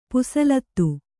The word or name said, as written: ಪುಸಲತ್ತು